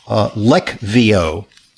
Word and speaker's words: Leqvio: Now, the medication that you're talking about, Levqo, or Lec, it's tough to pronounce because it's got a Q in it. It's called Lecvio.